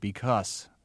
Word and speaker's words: because: One Brooklyn feature of your speech that I've picked up is the pronunciation of the word because, which you end with an S sound, not a Z, because.